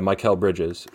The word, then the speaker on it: Mikal Bridges: Is it Mikal? Is that how you say it? – Yeah, Mikal Bridges.